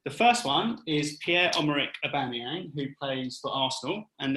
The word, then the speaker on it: Aubameyang: Aubameyang.